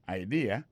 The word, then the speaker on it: idea: T-H-A-T, apostrophe S, good, G-O-O-D pour good, ça veut dire bon, bonne idea. C'est I-D-E-A. Ça veut dire idée. That's a good idea. Alors, accent vient tomber dans la deuxième syllabe. Là. One idea. Alors, moi, c'est idée. Vous faites trois syllabes vraiment. I-D-A.